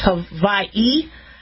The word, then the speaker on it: Hawaii: It's not pronounced Hawaii, it's Hawaii. I think it's pronounced with a V.